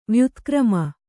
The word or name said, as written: ವ್ಯುತ್ಕ್ರಮ